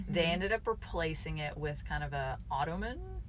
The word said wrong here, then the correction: automaton: hand. Is that how you pronounce that? – Like an automaton. There we go.